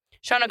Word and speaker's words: Shawano: Shawano County is spelt Shawano, S-H-A-W-A-N-O, but it's pronounced Shawano.